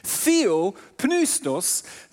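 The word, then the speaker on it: Theo, Pneustos (θεόπνευστος): Das Wort eingegeben auf Griechisch ist das Wort Theo, Theo, Pneus Pneustos. So tut man es aussprechen. Theo, Pneustos, das ist ein Wort, das aus zwei Worten zusammengeführt ist, nämlich Theos.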